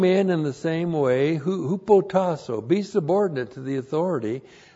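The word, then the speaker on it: Hupotasso (ὑποτάσσω): Verse 5, young men, in the same way, hupotasso, your pastor. Hupotasso, H-U-P-O-T-A-S-S, long O.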